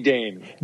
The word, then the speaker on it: Dain: Say, you, you can call me Dain.